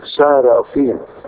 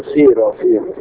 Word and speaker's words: سارافين: على فكرة اسمها سارافين، في ناس كتير ينطقوها سيرافين ومش سيرافين.